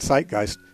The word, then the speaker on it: Zeitgeist: Zeitgeist is spelled Z-E-I-T, the German word for time, and Geist, the word for spirit, G-E-I-S-T. So Z-E-I-T, G-E-I-S-T, Zeitgeist.